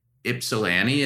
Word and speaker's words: Ypsilanti: Ypsilanti, is there one there? – Ypsi, yeah.